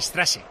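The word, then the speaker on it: Straße: Y se pronuncia como doble S, si te fijas en las calles, es Strave, se escribe con S y se dice Strasse.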